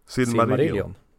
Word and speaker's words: Silmarillion: Men det här eh, similar, vad heter, vad heter den? Similar, jag kan inte uttala det. – Silmarillion. Ja.